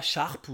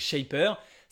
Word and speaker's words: Shapr: Donc, ça se prononce pas Sharp ou Chapre, hein, c'est Shapr.